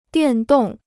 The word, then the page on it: 电动: diàn dòng